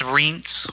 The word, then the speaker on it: Rients: It's Rients. – Rients, yep, that's what I thought. Like one syllable, yeah. You just go right across.